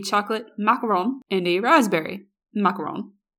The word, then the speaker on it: macaron: And my friend just looks at her and she goes, it's pronounced macaron. She goes, no, they're macaroons. And my friend goes, no, they are macarons. It's R-O-N and it's French.